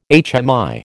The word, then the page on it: HMI: American English